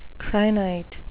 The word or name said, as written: crinite